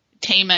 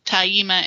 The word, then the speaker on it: Tama: I don't really, a lot of people ask how to pronounce names, like in False Hearts, Tama and Tyla is how I say it, but the audiobook actually says Taima and Tilla, so I was like, Oh well.